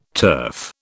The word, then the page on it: turf: British English